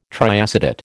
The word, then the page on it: triacetate: American English